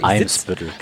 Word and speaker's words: Eimsbüttel: Ich sitze und schaue Ali Mitgut schwimmelbildmäßig auf den Weihnachtsmarkt von Hamburg Elmsbüttel. Hoffe ich zumindest, dass ich das jetzt richtig gesagt habe. – Eimsbüttel. – Eimsbüttel. Ich werde schon korrigiert hier von SideQuest und vom Recruitment-Blog.